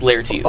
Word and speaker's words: Lhaertis: L H A E R T I S. Pronounced Laertes.